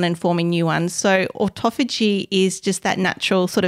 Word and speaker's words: autophagy: Autophagy. – Okay. – But when you read it, it's spelt like autophagy. – Okay. – It's pronounced autophagy.